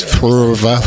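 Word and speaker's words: Phrva: This is Purva.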